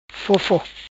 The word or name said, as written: Fofo